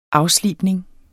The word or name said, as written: afslibning